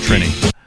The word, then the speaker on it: Trini: I think it's pronounced Trini.